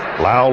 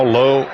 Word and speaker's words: Lowe: Lau, Lowe, all spelled the same.